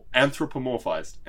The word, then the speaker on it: anthropomorphized: So very much an idealized and anthropomorphized. How do you say that word? – Anthropomorphized. Anthropomorphized, yeah.